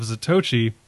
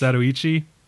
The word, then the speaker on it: Zatoichi: Don't miss your chance to check out the Quay Brothers, as well as the 2003 remake of Zatoichi. I just said that wrong, didn't I? It's Zatoichi.